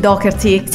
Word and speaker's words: Doherty: Amici fraterni, Pete Doherty, si pronuncia così a differenza di quanto molti pensano. – No, non è Doherty, no, pensa quanto ho sbagliato, mamma mia, ho sbagliato per anni.